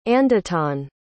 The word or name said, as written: andettan